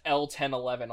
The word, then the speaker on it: L-1011: Okay, it is actually pronounced L-1011 also. I called it that because I was looking at the name, like, what do I even say for that? And it's funny that that's just what went to mind, and I just went to the page, and it's like, oh yeah, no, that is in fact how you pronounce that.